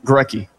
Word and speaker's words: Gorecki: Grecky LeBeau Stadium. – Grecky LeBeau. – Yeah. Not Linbo. LeBeau. – Gorecki? – Grecky. – Gorecki. – Don't ask me to spell it, but I think it's G-O-R-E-C-K-I, I think.